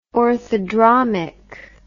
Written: orthodromic

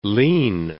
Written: lean

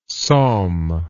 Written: somme

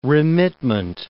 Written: remitment

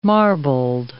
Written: marbled

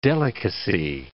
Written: delicacy

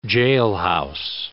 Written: jailhouse

